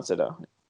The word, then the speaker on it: Sydow: Is that how you're actually supposed to say his name? – I think it's Max von Sydow, right? – Or is it Sydow? – I thought it was Sydow. – Well, it's Sydow. It's Sydow.